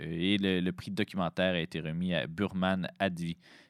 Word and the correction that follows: Abdi: Abdi, pardon, du néerlandais Douer Distra.